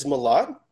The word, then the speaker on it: Milad: And so this is Milad.